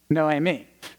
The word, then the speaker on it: Noemi: It's a Latin pronouncing of that middle name, and I can't get it. Naomi. Is that right? Naomi. Does that sound right? Noemi. Yes.